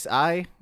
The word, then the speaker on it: Xi: It's XI? Like, how do you say that? Z? – I believe it's Xi. Xi is how I've heard it pronounced, I believe.